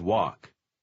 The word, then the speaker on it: walk: Помните, что буква L в последнем слове немая. – We always walk.